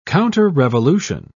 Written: counterrevolution

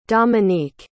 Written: dominique

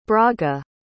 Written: braga

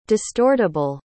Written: distortable

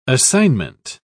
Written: assignment

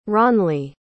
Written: ronli

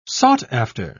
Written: sought-after

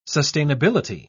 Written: sustainability

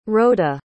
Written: rhoda

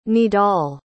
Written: Nidal

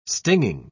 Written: stinging

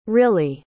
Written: rillie

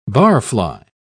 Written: barfly

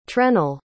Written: trennel

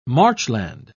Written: marchland

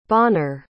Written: Bonner

